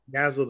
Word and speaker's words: Yzal: Yazzle the Praxis is my like official name.